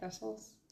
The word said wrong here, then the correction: Thestrals: Thestrals? – The actual horses.